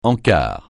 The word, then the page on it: encart: français